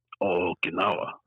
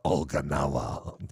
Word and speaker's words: Okinawa: Okinawa. – Okinawa.